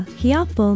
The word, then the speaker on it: Hiapo: Hiapo means firstborn. It is spelled H-I-A-P-O with no diacritical pronunciation marks, and it is used often in Hawaiian to describe the eldest child, the firstborn.